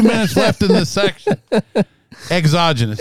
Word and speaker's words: exogenous: And so you're going to have these, you know, exogenous, you know, things that come out. – You didn't pronounce it right. How many times did I tell you? – Genius. – Exogenous. – Daggone it.